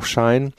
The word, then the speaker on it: Shein: Also das wird unterschiedlich ausgesprochen. Also da der Name von She kommt, sie, äh, kann man Shein sagen, einige sagen auch Schein. Also verzeiht mir, wenn ich das mal durcheinander bringe jetzt, aber ähm, Shein ist eigentlich ein relativ neues Unternehmen, gibt es seit 2008.